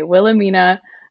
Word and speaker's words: Wilhelmina: Wilhelmina.